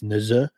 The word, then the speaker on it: Nezuh: Jackson Neza, I believe I'm saying that right. It's Neza, N-E-Z-U-H.